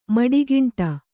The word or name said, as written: ಮಡಿಗಿಂಟ